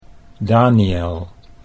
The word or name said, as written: Daniel